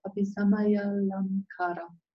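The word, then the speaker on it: Abhisamayālaṃkāra: The Sanskrit name of the text is called Abhisamya Alamkara. – Der Sanskrit-Tupi ist Abhisamaya Lamkara. – Wow, you got the long A good.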